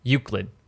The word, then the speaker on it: Euclid: So the object class is Elucid, or how do I pronounce that? Actually, let's see. Euclid, actually. E-U-C-L-I-D, Euclid, which is a dangerous thing, right?